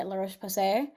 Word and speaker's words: La Roche-Posay: cream, or the Cicaplast by La Roche-Posay. God, I hope I didn't butcher that French. – No, you got it. You nailed it.